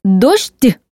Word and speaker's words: дождь: Слово «дождь» произносится с долгим мягким «ш» или с долгим мягким «ж» вместо сочетания «жды». «Дождь», «дождя», «дождичек», «дождем», «дождевик».